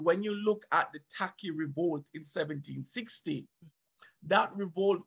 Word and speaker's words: Tacky: And Tacky, which is pronounced by, by um, the Akan people as Teche, Teche um, he organized it in that way where each parish would have a sort of chief.